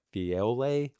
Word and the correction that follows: Phileo: Phileo. – And then there is agape. Jesus was saying, do you agape me? Peter goes, what is it again? – Phileo.